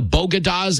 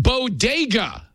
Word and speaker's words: bodega: Bogadas in the Bronx, except there's only one small problem. It's pronounced bodega.